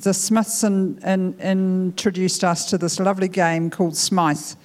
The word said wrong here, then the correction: Smite: And I've said it wrong, haven't I, AJ? What, tell me? – Smite.